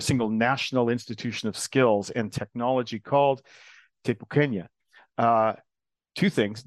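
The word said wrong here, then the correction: Te Pūkenga: No, the pronunciation wasn't great, but it's all about trying, really. We don't really expect you to have it right first time, but give it a go. It's TEPU Kenya. And it's a long U, and it's the ENG sound is very difficult for people who are not familiar with it to deal with.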